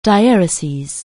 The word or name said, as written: dieresis